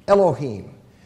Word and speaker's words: Elohim: Elohim. And the him, that is spelled H-I-M in English, but it's pronounced him in Hebrew, the him is a plural ending. So when you read in the beginning God, G-O-D, it's Elohim, there's a plural noun that's used there, so that is true.